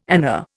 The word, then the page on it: ana: Indian